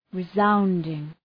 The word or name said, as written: resounding